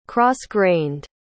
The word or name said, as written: cross-grained